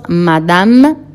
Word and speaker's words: Madame: Madame, la E final, obviamente ya aprendimos, no tiene tilde, no se pronuncia.